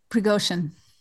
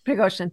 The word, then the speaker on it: Prigozhin: Well, I thought it was interesting because I hope I pronounced this man's name correctly. Yevgeny Prigozhin. – Yevgeny Prigozhin. – Prigozhin, thank you.